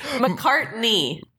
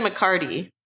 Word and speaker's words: McCartney: McCartney. – I said McCartney. – You said McCarty. – I said knee. – Listeners, he didn't have an N, and you know it. – Paul McCartney.